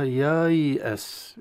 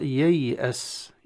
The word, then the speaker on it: ييأس: الدائرة الصغيرة هذه على الألف، وبالتالي الألف لا نطق لها، فيكون يا ثم بعد يا الثانية يي، والهمزة طبعا بين الياء وبين السين، ورمزت لها بشكل رأس العين الهمزة القطعية. – نعم. – فتقرأ يي أس، يي أس، يا مفتوحة ويا ساكنة.